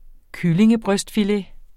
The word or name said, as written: kyllingebrystfilet